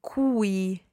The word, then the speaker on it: cui: Sì, it becomes cui. – Cui, okay. Now that's spelled C-U-I, cui.